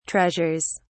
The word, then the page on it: treasures: British English